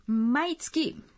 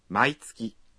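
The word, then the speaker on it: Maitsuki (毎月): Maitsuki. – Exacto. Vamos a practicar la pronunciación de estas expresiones. Todas las semanas. – Maishu. – Todos los meses. – Maitsuki.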